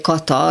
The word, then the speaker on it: Kata: Mert ugye Kata, az náluk egy ilyen, kicsit ilyen kiejthetetlen, és akkor mondtam, hogy K-A-T-A.